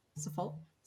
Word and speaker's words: Suffolk: Did I pronounce that right? Suffolk? Suffolk? – Suffolk.